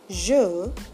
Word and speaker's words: je: When you pronounce je, you have to lightly brush the roof with a slightly rounded tongue, enough to let some air pass. Try it. Je, je, je.